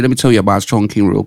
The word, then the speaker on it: Chongqing: Is it Chong? How you say it? Chongqing's. Yeah, Chongqing.